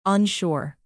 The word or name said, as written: unsure